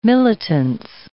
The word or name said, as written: militance